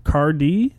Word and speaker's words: Qadree: Cardi. Is that how you say it? – Yeah, Q-A-D-R-E-E.